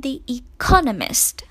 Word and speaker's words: The Economist: 那么最后一个《经济学人》 The Economist，注意哈，很多同学这个单词的发音都发得不太准，会把重音读错了。记住呢，应该是 And The Economist， OK， The Economist，不要把重音放在第一音节了。